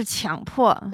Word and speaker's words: 强迫: 我来注意一下发音，是强迫。